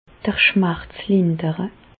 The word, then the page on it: d'r Schmerz lindere: Bas-Rhin; Reichshoffen